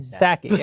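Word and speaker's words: Psaki: Saki. Saki.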